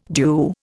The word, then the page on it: DEW: Indian